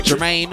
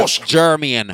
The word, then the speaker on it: Jermaine: That's how you say it like an American. Jermaine. Well, if you say it like a Jamaican, Jermian.